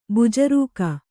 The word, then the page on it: ಬೆಜ್ಜರಿಕೆ: bejjarike